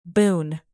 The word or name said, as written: boon